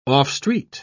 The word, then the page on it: off-street: British English